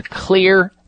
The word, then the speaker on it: Xlear: I would walk into a health food store and purchase something called Xlear nasal wash or Xlear, and it's spelled X-L-E-A-R. The X stands for xylitol.